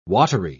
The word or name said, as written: watery